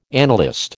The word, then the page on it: annalist: American English